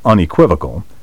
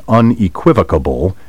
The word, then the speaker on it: unequivocal: No matter who you hear saying unequivocable, it's incorrect a beastly mispronunciation. Unequivocal ends with vocal, V-O-C-A-L, not vocable, V-O-C-A-B-L-E. Take care to pronounce the word in five syllables. Unequivocal. Word 29. Malleable. M-A-L-L-E-A-B-L-E.